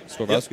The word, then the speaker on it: Swarovski: Um, I use uh, and I've I feel stupid because I don't think I've ever known how to pro- pronounce this, but Swarovski or Swarovski. – Swarovski. Yep.